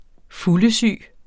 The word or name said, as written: fuldesyg